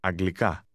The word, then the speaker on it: Αγγλικά: Did you notice that the speaker stressed the last part of that word? Once again, say English. – Αγγλικά.